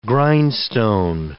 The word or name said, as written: grindstone